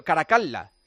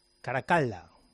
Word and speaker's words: Caracalla: Que tienes que decir Una sílaba acaba en L y la siguiente sílaba empieza por L. – Los idiomas no son los suyos. – Es bastante sencillo. Caracalla. – Caracalla.